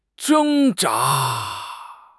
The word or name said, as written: 挣扎